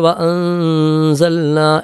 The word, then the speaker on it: وأنزلنا: അതുപോലെ വഅൻസൽന എന്ന് പറയുമ്പോൾ ഇഖ്ഫാ ബിഗുന്നയാണ്, മണിച്ച് അവ്യക്തമാക്കി ഓതണമെന്നുള്ളതാണ്.